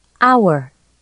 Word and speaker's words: hour: The letter H is not pronounced in the following words. Listen and repeat these words and sentences. – 1. Hour.